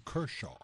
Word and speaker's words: Kershaw: Así que ya saben que hay fiesta en casa de los Kenshaw. Kenshaw, sí. Pronúncialo. – En buen americano, Kershaw. Okay, Clayton, bueno. Kershaw.